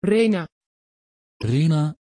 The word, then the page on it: Rhena: Dutch